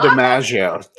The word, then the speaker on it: DiMaggio: Kiswell, that owns one of Marilyn's properties too, that when she was married to Joe Miaggio, you can't say his name. – Joe DiMaggio. Yeah. Joe DiMaggio.